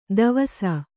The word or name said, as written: ದವಸ